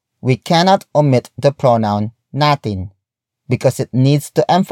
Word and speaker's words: natin: The next word is natin. Natin. Natin. Natin is spelled as N-A-T-I-N.